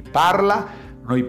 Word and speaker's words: parlano: Yo parlo, tu parli, lui parla, noi parliamo, voi parlate, acá lo vamos a hacer otra vez. Loro parlano, accento en la terzultima.